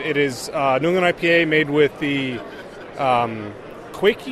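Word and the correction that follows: Kveik: yeast? I don't know if I'm pronouncing it right. It's the Norwegian yeast. – The Kvike? – Kvike yeast. – That's the way I'm saying it.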